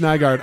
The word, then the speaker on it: Nygaard: Can you pronounce that again? – Bob Nygaard, private eye. – I don't know. Doesn't sound right. – Like, Nygaard. You're mispronouncing that last name. – I promise you it's Nygaard.